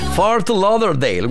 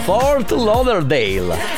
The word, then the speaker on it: Fort Lauderdale: Questa è la pronuncia. Fort Lauderdale. Guarda qui, Fort Lauderdale.